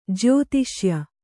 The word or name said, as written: ಜ್ಯೋತಿಷ್ಯ